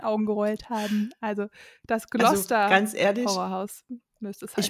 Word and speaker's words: Gloucester: Und ich habe noch gesagt, nein, ich glaube, es heißt Gloucester. Leider ist es total beides falsch. Es wird nämlich Gloucester ausgesprochen. – Oh Gott, das ist ja völlig verkehrt. – Aber es ist scheinbar, es sind so ein paar schottische Namen, die man irgendwie total anders ausspricht.